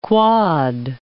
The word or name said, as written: quod